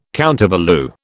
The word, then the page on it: countervalue: British English